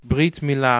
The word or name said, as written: britmila